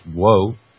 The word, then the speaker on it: Guo: Terry Guo, G-O-U, they say pronounced G-W-O, I would imagine that's Woe, were thrown into the spotlight, subjected to unfamiliar scrutiny by customers, labor activists, reporters, academics, and the Chinese government.